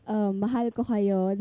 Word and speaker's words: mahal ko kayo: Okay, how do you say it? – It's mahal ko kayo. – Mahal ko... – Kayo. – Yeah, awesome.